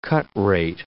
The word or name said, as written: cut-rate